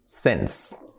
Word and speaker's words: since: Y la tercera que decimos, que es since, escrito since, pues la pronunciación correcta es since. Since.